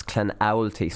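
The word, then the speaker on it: Clonoulty: So I messed up. I was Clanulty, but it's Clanowlty. So they're actually breaking it down into three different syllables for me to actually be able to pronounce this. So it was Clan, Owlty, Clanowlty.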